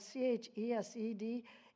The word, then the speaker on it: chesed: It's spelled C H E S E D in, in uh, Hebrew. And it, it, chesed, it's chesed, or it's, it's that, the, such the, the pure love of God we can't even imagine it.